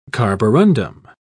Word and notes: carborundum: British English

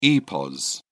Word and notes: epos: British English

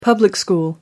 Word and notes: public school: American English

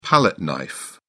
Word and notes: palette knife: British English

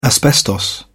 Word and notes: asbestos: British English

